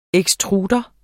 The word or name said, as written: ekstrudere